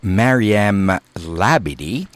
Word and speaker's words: Mariem Labidi: That was Maryam Labidi, pronounced Maryam Labidi, and a song called Matwaddanish.